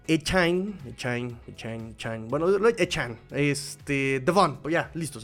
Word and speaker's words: Achane: no se pronuncia Echain, se pronuncia Echain, e, Echan, Echan. Es que tiene un ahí como al final, al final, al final tiene un ahí muy, muy, pero no para decir chain como de cadena, sino para decir Echai, eh, chain, ¿No?